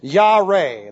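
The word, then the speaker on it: Yare (ירא): And I want you to notice, this time it looks like this. Yare. That would be J-A-R-E. And just make it a long E.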